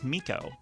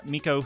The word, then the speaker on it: Meco: And this week's Chart Stat of the Week is actually about an artist whose name I have difficulty pronouncing. – Nice. – But I believe it's Miko, M-E-C-O. Miko, who, of course, was famous for hitting number one on the Billboard Hot 100 on October 1st, 1977, this week in 1977.